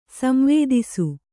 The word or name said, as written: ಸಂವೇದಿಸು